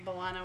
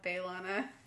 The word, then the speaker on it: B'Elanna: It went from an E to a schwa. – Yeah, it's like, yeah, most people call her like, to B-L. – B'Elanna. – B'Elanna. – Yeah, B-U-H, B'Elanna.